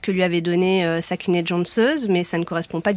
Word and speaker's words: Cansız: Cansız, vous prononcez Cansız. Hein. – Cansız. – Cansız. Et ça s'écrit Cansiz. Alors, moi, j'ai prononcé à la française. – Bien sûr. – Hein. Mais ça se prononce comme ça, d'accord.